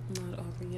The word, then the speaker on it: Ahmaud Arbery: Ahmaud Arbery. Am I saying his name right? Yes, I believe so. His um, Ahmaud Arbery.